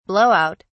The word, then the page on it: blow out: British English